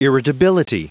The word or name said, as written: irritability